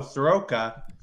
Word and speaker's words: Soroka: He just adds an extra O, Soroka. – Probably because you know what? Mike is a very smart guy because he figured out how to get people to stop mispronouncing the name. – Yeah. – You throw in that extra O and everyone's pronouncing it properly.